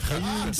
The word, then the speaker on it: Reims: Eu sei que é difícil Reims. pronunciar porque se, se, escreve R-E-I-M-S. Aí o pessoal diz Reims. – Atudão, ah, ah, ah, tudão soletrando é. isso. Mas se pronuncia é. Reims.